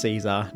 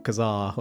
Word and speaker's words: Caesar: This is completely irrelevant to the episode, but I believe it is pronounced Caesar, not Kazar or something like that, because when the town was registered, they sent it off as Caesar, as in Julius Caesar spelling,